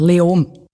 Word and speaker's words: Leoben: Und da gibt es die Stadt Leoben. Ich spreche sie wahrscheinlich falsch aus. Spricht man es Leoben aus? Wie spricht man das überhaupt aus? – Fast kein Konsonant. Leom.